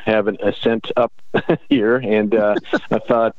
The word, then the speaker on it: Ascent: And I just said it wrong. I just caught myself. I even wrote it down. Ascent, not Ascent. Ascent Extracts.